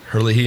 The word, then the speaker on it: Herlihy: All right, so like you already said, this is written by Tim Herlihy.